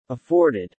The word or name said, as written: afforded